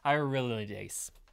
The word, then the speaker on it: hyaluronidase: How do you say that? – Hyaluronidase.